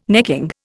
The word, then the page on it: necking: Indian